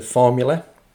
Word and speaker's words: Phormula: So those last two tracks from our release of the week this week, which is from Mr. Formula. That's Formula with a PH. So it's spelled PHORMULA.